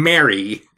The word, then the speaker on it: Merry: And Mary.